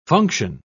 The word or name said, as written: function